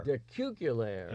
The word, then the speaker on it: DeCuculaire: It's very easy to pronounce. It's decuculaire. – Decuculaire. – That's right. – Okay, so there's a little cuque in there, right. – A little lair in there. – Just the way it's spelled. – And a little D.